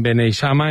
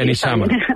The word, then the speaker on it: Beneixama: Bueno, Beneixama, pero a decir Beneixama.